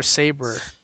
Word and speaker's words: Sabre: Do you pronounce it Sabre or Sabre? – Sabre, even though it's B-R, I mean, B-R-E.